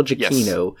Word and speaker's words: Giacchino: Uh, now, Michael, how do you say it? Giacchio? Giacchino. Giacchino. Michael Giacchino, yes.